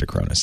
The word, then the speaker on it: Acronis: I asked Acronis, and they said that's how you say it.